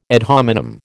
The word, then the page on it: ad hominem: American English